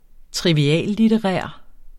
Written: triviallitterær

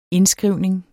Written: indskrivning

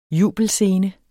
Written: jubelscene

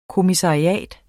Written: kommissariat

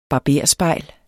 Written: barberspejl

